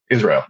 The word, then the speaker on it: Israel: Like the country, yeah. Israel.